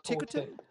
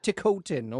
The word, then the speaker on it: Ticotin: How do you say her name? Rachel... Tyserton? – Ticotin? – Ticotin. Okay, Ticotin.